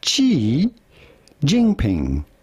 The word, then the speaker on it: Xi Jinping: They've got a player, I'm sure you've heard about, Cantor, who was praised for calling Xi Jinping. I think I pronounced that right, maybe. – Xi Jinping.